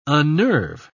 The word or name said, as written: unnerve